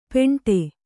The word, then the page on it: ಪೇಂಠೆ: Kannada